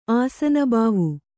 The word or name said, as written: ಆಸನಬಾವು